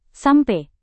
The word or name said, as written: sampe